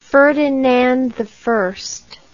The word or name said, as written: ferdinandi